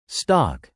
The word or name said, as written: stock